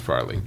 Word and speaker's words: Farrelly: No, but it's pronounced Farrelly.